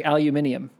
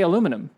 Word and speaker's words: aluminium: But so they say aluminium, mm-hmm. – right? So here we say aluminum.